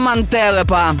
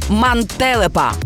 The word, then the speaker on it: мантелепа: Наголос на другому складі – мантелепа. Бо якщо скажеш «мантелепа», звучатиме дуже дивно, ніби новий супергерой.